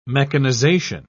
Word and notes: mechanisation: British English

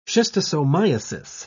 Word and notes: schistosomiasis: British English

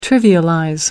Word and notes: trivialize: American English

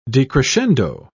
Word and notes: decrescendo: British English

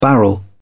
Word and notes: barrel: British English

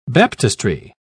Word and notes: baptistry: British English

